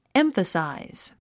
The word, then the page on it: emphasize: émfəsàiz; エンファサイズ